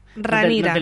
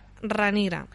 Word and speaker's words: Rhaenyra: Rain, uh, es Ranira. En la que llaman Ranira. – Ranira. – Se escribe Rhaenyra. – Rhaenyra. – Sí, como Daenerys. exacto.